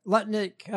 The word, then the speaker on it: Lutnick: Lutnick, I said it right.